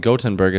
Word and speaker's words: Göteborg: A single flight between Sweden's two biggest cities, Stockholm and uh, Gothenburg, or probably Göteborg is how they say it, generates as much CO2 as 40,000 train trips.